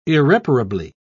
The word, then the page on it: irreparably: British English